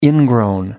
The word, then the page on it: ingrown: British English